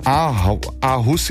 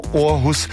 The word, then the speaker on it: Aarhus: In den letzten 4 Jahren ist mir ein Festival in Dänemark, in der dortigen Stadt Aarhus, die ich gelegentlich auch mal falsch als Aarhus, jetzt hätte ich schon fast wieder Aarhus gesagt, die ich falsch ausspreche, also Aarhus, dieses Festival ist mir sehr lieb geworden und es findet einmal im Jahr im Mai statt.